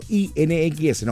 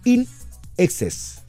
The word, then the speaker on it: INXS: O al pronunciarlo en inglés, INEXES, pero separado. No I-N-X-S, no. Es INEXES.